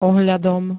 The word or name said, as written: ohľadom